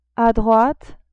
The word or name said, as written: a droite